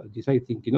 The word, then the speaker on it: design thinking: design thinking, como me dice César, para que lo pronuncie bien, para que pueda ser aceptado, design thinking, la experiencia del usuario.